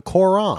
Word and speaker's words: Quran: Did you say the Quran? The Quran. – Quran. – Okay. I'm sorry. I just had to correct you there, dude.